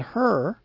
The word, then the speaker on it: Hur: And Moses, Aaron, and Hur went up to the top of the hill. Hur is spelled H-U-R, the name of a man, evidently.